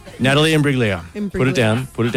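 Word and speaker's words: Imbruglia: Soft G. – Imbriglia. – Bang. – Imbriglia. Natalie Imbriglia.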